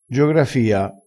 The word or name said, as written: giografia